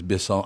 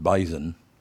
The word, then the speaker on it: Bisson: That Yannick Bisson, that's how you say his name is Bisson. I'm sure his name isn't Bison. It's Bisson, probably, right? – Bisson.